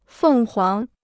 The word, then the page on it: 凤凰: fèng huáng